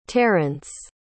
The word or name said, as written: tarrence